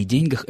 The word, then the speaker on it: деньгах: что в слове «деньги» во множественном числе во всех падежах ударение должно падать все-таки на первый слог, в отличие от единственного числа. Здесь просто такая парадигма, такова акцентологическая система склонений этого существительного. И уж тем паче нельзя утверждать, что «деньгами» и «деньгах» — это это неправильное и неграмотное употребление. Вот это, еще раз повторим, это есть правильная, старая, старшая норма и ни в коем случае не ошибка.